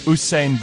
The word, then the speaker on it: Usain: St. Bolt. – Who? – St. Bolt. – Who's that? – The runner. – Usain Bolt.